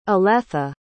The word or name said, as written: Oletha